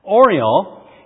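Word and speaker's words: Orual: And it's the story of a woman named Oriol. Kind of like Oreo with an L. Oriol, I guess I'll pronounce it.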